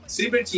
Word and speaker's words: Sibert: Como que você pronuncia? Será Siebert ou Zibert? E quantas vezes já erraram o teu, nome, sobrenome? Siebert. – Siebert e, e erram praticamente todas as vezes que vão falar. Principalmente falam Siebert.